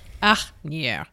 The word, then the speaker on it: Asnières: Asnières. Asnières. – Spell that for me. – A-S-N-I-E, accent, R-E-S. – Asnières.